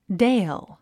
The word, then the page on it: dale: déil; デイル